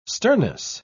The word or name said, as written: sternness